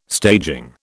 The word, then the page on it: staging: British English